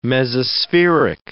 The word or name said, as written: mesospheric